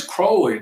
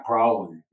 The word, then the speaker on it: Crowley: Well, anyway, we're going to talk about Crowley and Slam a minute. – Yeah. I mean, fiction like me. So anyway, there's the old Crowley. – The beast. – And it is Crowley, not Crowley.